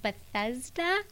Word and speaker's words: Bethesda: they called it the Pool of Bethesda.